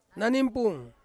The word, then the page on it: nanimpung: Ansus